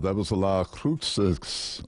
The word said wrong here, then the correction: Las Cruces: Let's try that again. La Cruces, a brand new album. Boy, I'm not saying that correctly, am I? Silent Night. Boy, it is a really good album. Las Cruces. Let's go with that.